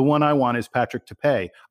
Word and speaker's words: Tape: Yeah, and first of all, the most important thing, his last name is spelled T-A-P-E, but you are correct. It is not pronounced tape like scotch tape. It is tape. And in fact, DBR poster Brevity came up with a great way for people to remember how to pronounce his name. He said, if an opponent tries to challenge our front court, they'll be hell to pay.